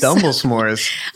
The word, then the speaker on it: Dumble s'mores: It's like Dumbledore, right? – Dumbledore. Dumble s'mores.